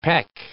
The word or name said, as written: pec